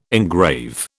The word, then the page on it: engrave: British English